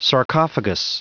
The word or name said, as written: sarcophagus